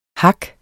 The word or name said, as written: HAKKENES